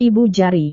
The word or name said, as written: ibu jari